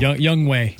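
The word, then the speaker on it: Younghoe: No, it's Young Ho Koo. – Young Wei. That's how it's pronounced. – Oh, that's pronounced Wei. – Young Wei Koo. – Yeah, it's spelled Ho, though. – That's it is. – Young Ho. – It is. It doesn't make much sense.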